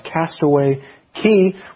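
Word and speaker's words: Castaway Cay: Well, in this section, Carolyn is talking to us about Castaway Key. We read, perhaps my favorite day was at Castaway Key. Now, again, that's spelled C-A-Y, but pronounced key in this case.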